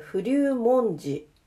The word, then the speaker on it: 不立文字: はい、えー、不流文字。不流文字。どっち、ちょイントネーションわかりません。不流文字。えー、えっ、ー、と、不、不安の不に、立つに、文字と書いて、不流文字。